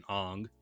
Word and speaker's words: Aang: Like, first of all, if you're going to cast Aang, who they called the proper pronunciation Aang, why is he white?